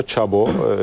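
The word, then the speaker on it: Chabot: Babası Alman, annesi Fransız. 5 dil birden konuşabiliyor. Çabo. O yüzden de ismi Çabo diye okunuyor.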